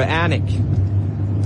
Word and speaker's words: Alnwick: We're heading for Alnwick. But they spell it A-L-N-W-I-C-K.